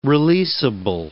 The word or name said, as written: releasable